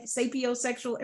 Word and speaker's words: sapiosexual: saposexuals, sap, sapiosexual. Am I saying this right? – Yes, you're saying it right.